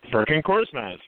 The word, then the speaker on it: Furkan Korkmaz: But it's Furkan Korkmaz. – Furkan Korkmaz, very nice.